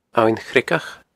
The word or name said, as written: Abhainn Chreagach